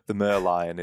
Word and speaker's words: Merlion: Just walking around the city, seeing now, I hope I don't butcher this pronunciation. Merlion Park, Merlion. – The Merlion. It's Merlion, isn't it? – It's the Merlion. It's Singapore's national icon.